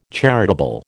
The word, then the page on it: charitable: American English